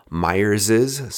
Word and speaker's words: Myers's: It is Meyer's Rum Original Dark, although it is spelled Myers's with an S, apostrophe S, which is not how you would normally do it if your English grammar teacher would tell you. You just have the apostrophe after the S. You don't need the second S. But Meyer's is spelled M-Y-E-R-S, apostrophe S. So Myers's, sometimes people pronounce it that way. I'm going to say Meyer's. We'll leave it at that.